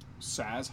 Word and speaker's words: Saaz: Saez hops. I think I'm pronouncing that correctly. Saez. S-A-A-Z. – Saez hops? – Is it Saez hops?